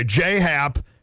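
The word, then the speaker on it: J.A. Happ: Just say it like it's J-A-Y and you're good. – Yeah, that's what I thought. Okay, J-Hap and John Lester.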